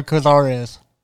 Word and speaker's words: Cazares: So you have been working with Dino Cazares. Is it Cazares or Cazares? – I believe he pronounced it Cazares.